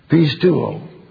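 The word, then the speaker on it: pisteuō: Pisteuo.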